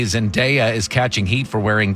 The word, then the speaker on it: Zendaya: And I know it sounds weird to say Zendaya, but that is how you pronounce her name.